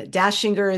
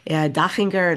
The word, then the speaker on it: Dachinger: It doesn't have an E at the end of Debbie, and Dashinger is not American, it's Austrian. So it's DACH, Dachinger, and all of that.